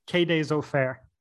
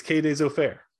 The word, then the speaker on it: Quai des Orfèvres: Quai des Orfèvres. – Quai des Orfèvres. It's Quai des Orfèvres.